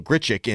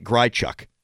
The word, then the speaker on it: Grichuk: Callis was very close to the microphone, and I think it's because he knows it's pronounced Randall Grichuk and not Grichuk. Is that it? – Yep.